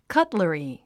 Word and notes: cutlery: kʌ́tləri; カトラリィ

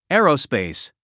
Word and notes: aerospace: é(ː)ərəspèis; エアロスペース

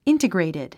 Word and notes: integrated: íntəɡrèitid; インテグレイテッド

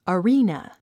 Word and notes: arena: əríːnə; アリーナ

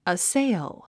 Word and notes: assail: əséil; アッセイゥ

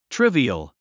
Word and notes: trivial: tríviəl; トゥリビアル

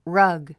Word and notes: rug: rʌ́ɡ; ラァグ

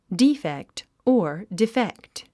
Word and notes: defect: díːfekt; ディーフェクト

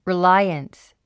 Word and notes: reliance: riláiəns; リラァイアンス

